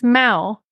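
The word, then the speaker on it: Mow: It's not meow it's it's Mao, like a really strong.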